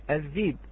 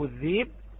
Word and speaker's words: الذيب: كلمة الذئب وردت في سورة يوسف عليه السلام وعن نبينا هو الصلاة والتسليم، قرأت في سورة يوسف في المواضع الثلاثة، في المواضع الثلاثة في سورة يوسف الذيب، وتكتب كما تلفظتها الآن.